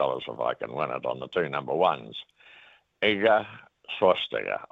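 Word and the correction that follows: Świątek: I call her Swastiger, but I can't pronounce her second name. – Swiatek. – Swiatek, yes.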